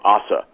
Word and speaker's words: Ose: This guy, I think you pronounce his name Ossa, mm-hmm. O-S-E.